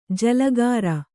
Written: ಜಲಗಾರ

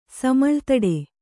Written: ಸಮಳ್ತಡೆ

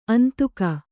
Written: ಅಂದಿಗ

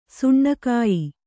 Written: ಸುಣ್ಣಕಾಯಿ

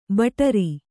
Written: ಬಟಾರಿ

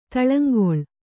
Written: ತಳಂಗೊಳ್

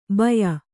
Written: ಭಯ್ಯಾ

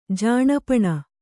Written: ಜಾಣಪಣ

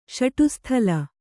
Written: ಷಡುಸ್ಥಲ

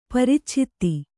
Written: ಪರಿಚ್ಛೇದ

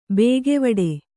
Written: ಭಾಗವಡೆ

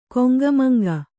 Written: ಕುಂಕುಮಾಂಕ

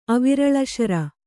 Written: ಅವಿರಳಶರ

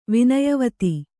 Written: ವಿನಯವತಿ

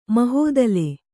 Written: ಮಹಾತಳ

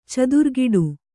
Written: ಚಿತ್ರಕುಟಿ

